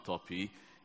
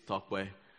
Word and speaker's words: Tope: But you just need to know that the correct pronunciation of my name is not Toppy, it is Tokwe.